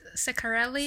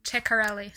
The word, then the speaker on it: Ceccarelli: Well, you can pronounce it Ceccarelli, Ceccarelli? Or Ceccarelli.